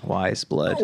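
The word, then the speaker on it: Weyes Blood: She, it's spelled W-E-Y-E-S, Wiseblood. – Oh, Wiseblood. – Yeah. So it's German. Wiseblood.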